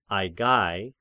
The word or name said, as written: Aigai